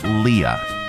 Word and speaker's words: Leah: 99.9% of the time on Earth, it's Leah. 99.9% of the time on Alderaan, it's Leia. You're welcome, nerds.